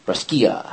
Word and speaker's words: Brescia: Brescia, how you pronounce it.